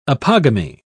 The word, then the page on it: apogamy: British English